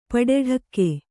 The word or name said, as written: ಪಡಡಕ್ಕೆ